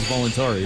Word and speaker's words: Voluntari: Voluntari? – Yes, sir. – I didn't want to sound like I'm a Flatlander here. Voluntari. It is Voluntari.